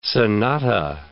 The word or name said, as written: sonata